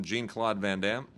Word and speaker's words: Jean-Claude Van Damme: So Tim Americanizes it, calls him Jean-Claude Van Damme, which I think is perfectly cool and fine. That's exactly how you would pronounce it if you're Americanizing it, and that is what Tim does, and I defend him for it.